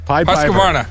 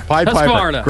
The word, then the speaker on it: Husqvarna: Husqvarna. – Husqvarna.